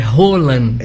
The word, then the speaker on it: Haaland: Haaland. – É, ele não parece. – Que a pronúncia correta é Haaland.